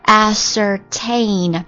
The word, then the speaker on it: ascertain: 这个单词的话叫做 ascertain。当然的，certain 那个单词都会写，对不对？前面呢加一个 as，读音变了 ascertain certain ascertain ascertain ascertain as 加上 certain 那个单词 ascertain ascertain ascertain 动词表示搞清楚确定查明确定查明 ascertain ascertain 例句我希望你去确认一下损坏的程度。